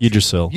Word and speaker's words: Yggdrasil: How do you say that fucking Yggdrasil.